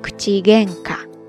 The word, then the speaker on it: 口喧嘩: 那如果你要专指口角上的争吵呢，你可以在这个单词前面加上口这个汉字，只不过这个时候发音呢需要浊音化。口喧嘩、口喧嘩、口喧嘩ですね。